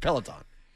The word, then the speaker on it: Peloton: He's got the Pedalton or whatever. Pedalton. – Peloton.